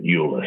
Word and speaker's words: Euler: Now, there are businesses insuring this, and the classic business insuring this is a French business called Euler Hermes, and I was talking to a Frenchman who pronounced it Euler, so I'll have to go with Euler.